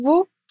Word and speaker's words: vous: Croissant, no plural em que também não pronunciamos a letra S, e o pronome vous, que também tem um S pendurado ali só de enfeite.